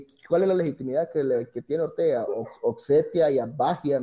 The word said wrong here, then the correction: Abjasia: no sé cómo se, ni siquiera se pronuncia. – Abjasia, Abjasia.